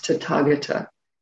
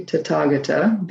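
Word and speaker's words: Tathāgata: So this word is pronounced Tathagata. Okay, Tathagata, based on some of those earlier rules I had.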